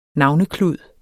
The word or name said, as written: navneklud